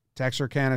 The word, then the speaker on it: Texarkana: The authentic way to pronounce it is Texarkana. Okay.